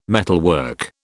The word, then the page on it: metalwork: British English